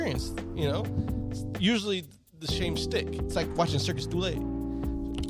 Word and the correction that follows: Cirque du Soleil: Cirque du Soleil. – Yeah, there it is.